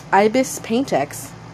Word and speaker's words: Ibis PaintX: It's spelled I-B-I-S, like the bird, Ibis. Paint is spelled in the traditional way, P-A-I-N-T. And then X is just the letter X, Ibis PaintX.